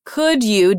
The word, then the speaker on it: could you: For example, could you do me a favor? And we hear the pronunciation of you changed a little bit. It sounds almost like a j, j sound.